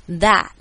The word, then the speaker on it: that: 的前面的这个蝴蝶音，就是如果你有学过 KK 音标，你就知道我在说的是那个 a 扁扁的 a 的音 that，然后 t 不要发音，变成 that this。